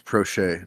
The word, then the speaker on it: Proche: James Proche, DeMarcus Robinson. – Garbage. It's pronounced Proche. It's French. Oh, that's a good point.